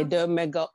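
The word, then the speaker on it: Les Deux Magots: how do you pronounce it? Le Deux Magots? Is that how you pronounce it? – Le Deux Magots. Mm-hmm.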